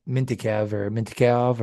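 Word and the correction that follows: Mintyukov: or how do you say his name again? – Minchikov.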